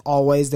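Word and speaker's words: Alvvays: For music, I have Always, and that's A-L-V-V-A-Y-S, pronounced Always, their debut album, self-titled Always.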